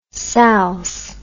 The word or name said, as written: salse